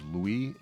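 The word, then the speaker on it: Loie: And we heard Going Home, and I'll pronounce this Louis. It's L-O-I-E.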